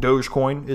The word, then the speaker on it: Dogecoin: Dogecoin, or as I've been informed, Dogecoin is the correct pronunciation.